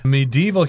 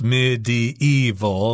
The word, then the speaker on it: medieval: Some people say, medieval, which is four syllables, medieval, but uh, I tend to kind of scrunch those two syllables together and just say medieval, three syllables.